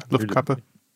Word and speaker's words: Luftkappe: How do you say that? Luftkappe? – Yeah, Luftkappe.